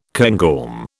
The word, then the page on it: cairngorm: British English